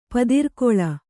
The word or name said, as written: ಪದಿರ್ಕೊಳ